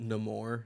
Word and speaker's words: Namor: I believe it's pronounced Namor now, Sean.